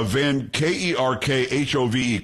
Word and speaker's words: Van Kerkhove: It's Van Kerkhove. Kerkhove. I'm pretty sure that's how you pronounce it.